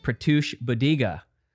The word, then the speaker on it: Pratyush Buddiga: I'm here with Pratyush Buddiga.